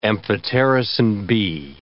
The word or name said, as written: amphotericin B